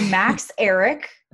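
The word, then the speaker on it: Max Ehrich: She's engaged to Max Eric. That is how you pronounce it, apparently.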